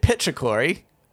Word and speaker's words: petrichor: Bit petrichory. – Is it so is it core or claw? – Core. – Petrichor. – See, it's see immediately. C-H-O-R. Immediately, I was saying it wrong again.